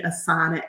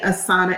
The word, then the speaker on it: Asana: I am also on social media at Bhumi Asana MD, Facebook, Instagram, LinkedIn.